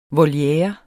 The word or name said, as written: Volierer